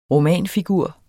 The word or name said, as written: romanfigur